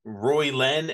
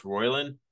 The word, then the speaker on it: Roylin: He's like, yeah, are you Roy Len, and he's like, it's pronounced Roylin.